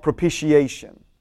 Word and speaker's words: propitiation: So we see that God was in Christ reconciling the world Part of your salvation is something called propitiation. Propitiation, or some, it's a hard T, you can pronounce it both ways.